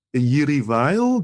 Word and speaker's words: Jiří Weil: Jiří Weil, do I pronounce that name correctly? – That's fine.